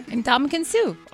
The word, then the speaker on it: Ndamukong Suh: Ndamukong Suh.